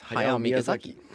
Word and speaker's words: Hayao Miyazaki: I found out that Hayao Miyazaki is pronounced as Hayao Miyazaki.